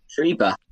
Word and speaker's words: Schreiber: His last name is Schreiber? Schreiber? – Schreiber. Yeah, Schreiber. Let's do that. – You say Schreiber.